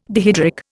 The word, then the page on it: dihydric: Indian